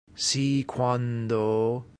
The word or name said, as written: si quando